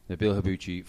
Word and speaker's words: Nabil Habbouche: Nabil Habbouche from Adventure Quest.